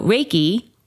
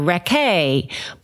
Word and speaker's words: Reiki: So Reiki, R-E-I-K-I, is pronounced Rei, like the sun, and Ki, which is energy. I get so many different ways to say it. People say Reiki, people say Reiki massage or Reke, but it is Rei Ki.